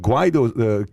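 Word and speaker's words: Guaidó: When we were speaking to Juan Guaido's camp, am I saying the name correctly? – Yes. – Juan Guaido's camp, he was going up against Maduro.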